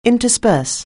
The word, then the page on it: intersperse: anglais